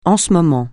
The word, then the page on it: en ce moment: francais